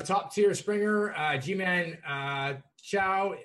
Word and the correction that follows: Choi: or Choi, sorry, in the CAS. I knew I was going to butcher that one.